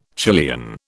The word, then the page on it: Chilean: British English